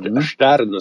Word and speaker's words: Stern: Ja, eller Stern skulle jag säga. – Ett sånt ljud i början.